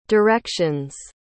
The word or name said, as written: directions